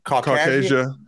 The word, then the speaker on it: Caucasia: How do you pronounce? Is it Caucasia? – Caucasia.